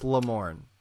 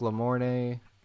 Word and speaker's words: Lamorne: How did you say it? – Shamor. – I think it's Lamorne. – That's why I said Lamorne. – Lamorne. It looks like Lamorne. – Yeah, Lamorne Morris.